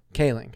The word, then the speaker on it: Kaling: Kaling.